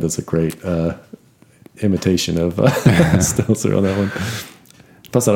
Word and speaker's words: Stelter: I don't think there's a Z in there. – Stelter. Like a seltzer.